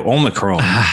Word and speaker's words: Omicron: Omicron? Is that how you say it? – That is how I've heard it most cited. Yes, Omicron.